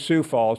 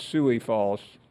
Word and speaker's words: Sioux Falls: Just to get the pronunciation correctly. It is Sioux Falls, not Suey Falls.